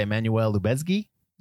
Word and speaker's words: Emmanuel Lubezki: Cinematography by Emmanuel Lubezki.